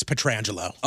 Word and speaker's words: Pietrangelo: And he goes, my name is Alex Pietrangelo.